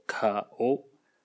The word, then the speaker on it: 顔: 日语里的脸是单一个言字，容颜的颜，当然也是颜值的颜，读作卡欧。